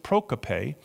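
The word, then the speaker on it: prokope (προκοπή): In the original Greek, it's prokope, simple way to pronounce it, prokope.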